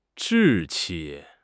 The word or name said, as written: 志气